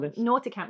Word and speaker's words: Nauticam: Okay, well, like nautical, yeah. Like of the sea. Yeah, nautical. Nauticam.